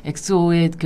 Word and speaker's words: 엑소: 뭐 엑소의 그 김준면 씨 같은 경우는 네. 발음을 엑소? 엑소. 엑스오라고 엑소. 네, 네, 네, 네, 엑소.